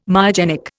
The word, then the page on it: myogenic: Indian